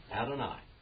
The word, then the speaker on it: Adonai: Adonai. Now, this is what all the Jews call the name Jehovah. That's how they pronounce it.